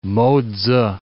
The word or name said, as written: Mo-tzu